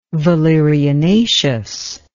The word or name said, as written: valerianaceous